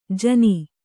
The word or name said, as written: ಜಾನು